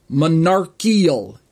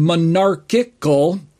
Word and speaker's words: monarchical: And if you Google search the phrase, you might find that some people refer to it as monarchial instead of monarchical. And so, to the best of my understanding, monarchical, with two C's in there, is the correct pronunciation. And those who are saying monarchial are simply mispronouncing it.